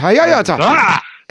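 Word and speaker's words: Hahoetal: Oh, no, you put the accent on the wrong word. It's Hahoyata.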